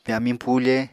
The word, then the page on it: pyamiñ pufe: Apatani